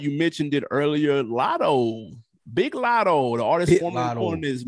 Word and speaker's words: Big Lotto: I'm a Big Lotto fan. You got to say it like you're from Atlanta. Lead a G off. B-I. Hard I. Big Lotto.